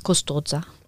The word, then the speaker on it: Custoza: Ja, also man spricht es Custoza. – Okay, oder Custoza. Nicht, dass ich es falsch jetzt die ganze Zeit hier ausspreche. – Es ist ne? – wie ein scharfes S, Custoza.